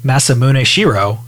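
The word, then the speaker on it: Masamune Shirow: But either way, they got the best person possible to make the story for it, Masamune Shiro, who I probably butchered his name, but he was the original manga artist and writer. – And I think you actually pronounced it pretty well, given basic American accents.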